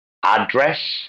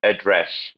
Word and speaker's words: address: So address and address, you know, where it's always on the noun, it's always the first syllable, but on the verb, it's always the second syllable.